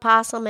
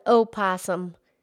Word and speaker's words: opossum: Yeah, well, Farmer Tommy calls it possum and Lady Net calls it opossum. You know, potato, potato.